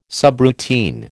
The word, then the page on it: subroutine: American English